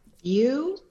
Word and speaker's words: Viau: View, just like it's spelled, Your Honor. – View? – View, as if it were V-I-E-W. – Oh, I see. Yeah, because it is spelled V-I-A-U.